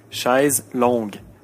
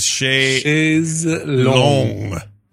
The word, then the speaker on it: chaise longue: The real French pronunciation is chaise longue with a hard G. Longe does exist in French, but it means loin.